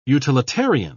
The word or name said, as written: utilitarian